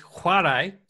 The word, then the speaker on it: chwarae: All right, your Welsh word for the day, Zach, and I want you to pronounce this for me, is spelled C-H-W-A-R-A-E. – C-H-W-A-R-A-E. That would be Chuarish. – It's Chuarai.